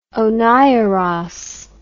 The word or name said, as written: oniros